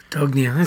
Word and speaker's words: Pedagogia: So as we keep going, as I'm looking through my list here, Thursday at 12 noon is Angel Frette, and if I pronounce this correctly, Ensemble de Percussion Pedagogia, is that right? Pedagogia? Is that right? Pedagogia? I don't know. My Spanish is not very good. – Pedagogia. Sounds right.